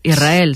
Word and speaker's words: Israel: Y la S con la R es difícil de pronunciar. La gente dice Israel, es verdad. – Que suene la S. Israel, sí, es difícil de pronunciar.